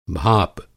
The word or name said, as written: भाप